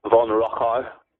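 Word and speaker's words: von Rochau: August Ludwig von Rocha. Although my pronunciation isn't much better. So if there are German scholars on the phone or listening to this, they might not like it.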